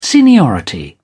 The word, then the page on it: seniority: British English